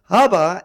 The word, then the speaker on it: Abba: Abba. That's how it's pronounced. Okay? Now, Abba is your dad.